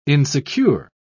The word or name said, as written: insecure